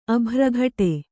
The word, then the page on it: ಅಬ್ರೂಗೇಡಿ: abrūgēḍi